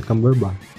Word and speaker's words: Cumberbatch: Fora que tem o Cumberbatch, Cumberbatch, assim que se fala. – Né? – Cumberbatch? – É, Cumberbatch. É, depende da pronúncia, mas é Cumberbatch.